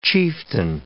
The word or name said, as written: chieftain